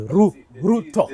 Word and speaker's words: Ruto: You see, not Ruto. It's Ruto.